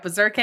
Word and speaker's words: Bezirke: Not berserker. Not berserker. Berserke. – Berserke? – Berserke. – Spell it. – B-E-Z-I-R-K-E.